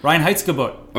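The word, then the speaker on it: Reinheitsgebot: Spotted Cow adheres to the, I'm going to try this, Rheinheitsgebot? – Yeah, I think that's pretty close. According to the Google Translate thing or whatever, where they record the pronunciation, it's like Rheinheitsgebot.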